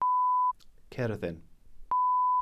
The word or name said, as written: cerddin